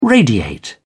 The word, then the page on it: radiate: British English